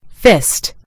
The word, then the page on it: fist: American English